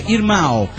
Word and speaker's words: irmão: Escribí antes, pero el programa de ustedes está necesitando quedar con mayor tiempo, dice Fernando, por favor, pirata, hermano en portugués, se dice irmão. Se pronuncia irmão, suena Irma.